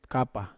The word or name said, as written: kapa